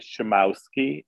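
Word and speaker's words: Szumowski: I understand it as Schumowski, and it's S-Z-U-M-O-W-S-K-I.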